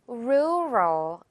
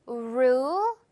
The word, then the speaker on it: rural: rural 이렇게 발음이 돼요. 이거 조금 발음하기 어려우실 겁니다. rural, rural 이렇게요. 마지막은 혀가 윗니 뒤에 닿도록.